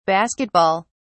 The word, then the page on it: basket ball: British English